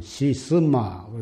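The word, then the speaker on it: 시스마 (是甚麼): 한문으로 하면 시신마, 그러기도 하고 시산마 하고 중국 발음으로는 시스마, 그런데 이것이 무엇인고 그 말인데 경상도의 사투리로는 이 무엇고거든.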